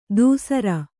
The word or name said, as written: ದುಸ್ಸರ